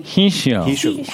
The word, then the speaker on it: hishio: So you said, what is it called? Hashi? – Hishio. – How do you spell that? – H-I-S-H-I-O, yeah.